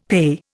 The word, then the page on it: PEI: Indian